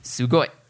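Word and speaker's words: Sugoi (すごい): Sugoi. – Sugoi.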